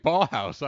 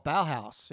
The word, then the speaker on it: Ballhaus: You're right, Ballhaus. I pronounced it wrong. Ballhaus. Ballhaus. – I originally thought Bauhaus, you know, being the music guy. I was like, oh, Bauhaus.